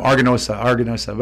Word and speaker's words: Aganorsa: Yeah, it's four syllables. It's not like a super difficult thing. But yeah, and again, I hear it all the time, Aganorsa.